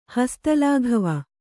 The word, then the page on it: ಹಸ್ತಲಾಘವ: hasta lāghava